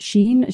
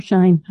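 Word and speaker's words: Shein: Is it Sheen? Sheen or Shine? Do you know how you pronounce it? – I think it's Sheen.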